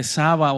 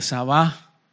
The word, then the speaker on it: Sabá: En algunas traducciones le ponen el acento en la última A y otras no traen acento. La que no trae acento es Saba y la que trae acento es Sabá.